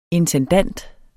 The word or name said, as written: intendant